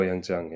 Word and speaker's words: Yangjiang: in Yangjiang. – Genau, Yangjiang, ja.